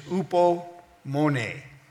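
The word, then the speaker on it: hupomone: In the Greek, the Greek word for patience is upomone. Upomone means to abide under. Upo, that's how it's spelled in the Greek. Upo is under, and meno means to abide.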